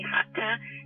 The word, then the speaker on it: hatha: Yeah, most people pronounce it hatha, but it's actually hatha, and it means sun and moon, and it's like a balance.